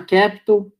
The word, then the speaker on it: KPTL: Então, estou há três anos na Capital, é, KPTL, pronuncia-se Capital, a gente é uma das maiores casas de, de venture capital do Brasil.